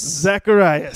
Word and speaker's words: Zacharias: Zacharias.